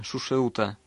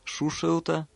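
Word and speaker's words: Сушрута: Дело в том, что в санскритском языке есть три буквы «ша». Одна произносится как «с», другая как «щ», и третья как «ш». И вот имя Шушерута написано с той буквы, которую очень сложно правильно произнести.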